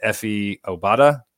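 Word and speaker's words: Efe Obada: So I think, and then it looks like as far as re-signing goes, they did re-sign, is it Efi Obada?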